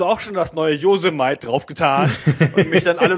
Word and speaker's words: Yosemite: Ich habe ja auch lange gebraucht, bis Apple die neue Betriebssystemversion rausgebracht hat, dass es nicht Yosemite, sondern Yosemite heißt, diese Gegend da in Amerika.